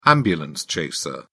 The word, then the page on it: ambulance chaser: British English